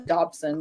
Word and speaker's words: Dodson: also got Mark Dawson will be who will be there, and Mark Dodson uh, Dodson. Let me say that correctly.